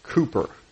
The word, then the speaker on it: Cowper: There was I think I mentioned a few weeks ago, William Cooper, right? C-O-W-P-E-R. If you were Canadian, you'd call him Cowper, but the British mispronounce everything for some reason. They call him Cooper.